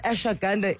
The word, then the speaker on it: ashwagandha: And finally, we're going to have this conversation about ashwagandha. Am I saying it right? – Ashwagandha, yes.